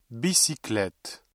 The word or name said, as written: bicyclette